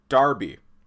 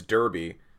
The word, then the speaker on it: Derby: So, Darby. It's spelt Derby. It's the same word as Derby, but the English pronounce it Darby.